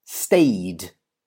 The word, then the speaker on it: stayed: So in the past, stayed, stayed. It finishes in a Y sound, stay. So you do not add an extra syllable.